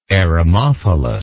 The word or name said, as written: eremophilous